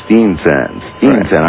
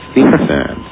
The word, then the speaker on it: Steensen: S-T-E-E-N-S-E-N. – You know, pronounce it for me. – Stenson. Danish. – Stenson. Right. I might be able to do that. Stenson.